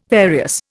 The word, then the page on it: various: Indian